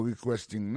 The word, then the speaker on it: requesting: Dans mot requesting là, ouais, accent tombé sur deuxième syllabe là, requesting politely.